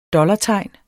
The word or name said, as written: DOLLARTEGN